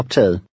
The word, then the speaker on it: optaget: In number 15, the last G is silent, and the final T sounds like an L. – Læs nummer 15. – Beklager, der er optaget.